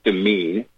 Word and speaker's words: demesne: Seized in domain, and domain is spelled D-E-M-E-S-N-E. It's also pronounced demean.